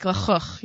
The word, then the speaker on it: lachuch: You what really have to be Israeli to say it. It's like l'chuch, you know, a lot of chuz.